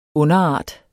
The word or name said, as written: underart